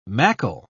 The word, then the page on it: mackle: British English